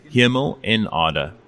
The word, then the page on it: Hemel-en-Aarde: South Africa